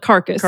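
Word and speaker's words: Carcas: and Carcass. Good Lord. – Wow. – Carcass, literally spelled like carcass. Carcass. C-A-R-C-A-S-S.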